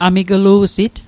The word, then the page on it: amiglu'sit: Mi'gmaq